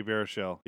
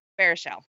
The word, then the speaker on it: Baruchel: Jay Baruchel, yeah. – Baruchel, thank you.